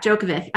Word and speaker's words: Djokovic: We, of course, are talking about Novak Djokovic.